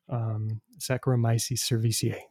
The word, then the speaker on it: Saccharomyces cerevisiae: but I've got to pronounce it right. Saccharomyces, you know, a class of yeast that are very common for brewers, bakers, Saccharomyces cerevisiae. There we go.